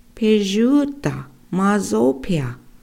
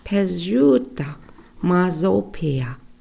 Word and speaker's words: pijuta mazopia: So for drugstore in our language, we say pijuta mazopia. Pijuta mazopia. Okay, let's pronounce that together.